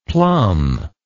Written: plomb

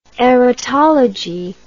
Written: erotology

